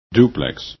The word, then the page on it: duplex: english